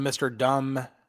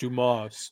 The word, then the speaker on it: Dumas: He was calling him Mr. Dumb. I don't want to say things because I don't want to lose. Yeah. He was like, Mr. Dumb, and saying like that, but it's pronounced Dumas.